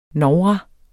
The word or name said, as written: novra